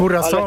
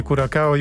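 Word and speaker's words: Curaçao: Jak Curacao, ale... bo to chyba tak się wymawia, nie Curacao i Uzbekistan.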